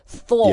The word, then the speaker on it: Thor: 对，这个发音大家注意，不是 Thor, 是 Thor. – Yeah, Thor. – T-H-O-R, Thor.